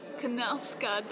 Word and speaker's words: Knausgaard: Ah, and then older Carl over Knausgaard, that's what they were saying. – Yeah. – They were pronouncing the K. – Yes, so definitely pronounced that wrong on the actual podcast, but that's fine.